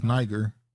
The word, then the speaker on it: Niger: It's Niger. Well, I'm sorry. I guess I was saying that wrong. They said it's Niger.